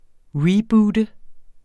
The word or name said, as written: reboote